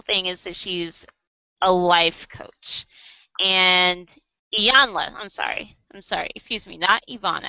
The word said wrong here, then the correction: Iyanla: I knew I was saying her name wrong. Iyanla.